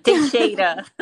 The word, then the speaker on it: Teixeira: Teixeira.